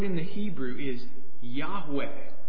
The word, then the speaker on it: Yahweh: But when he's revealing his personal name here, "I am," Yahweh is how it's pronounced, he's showing us how he is a God who is close.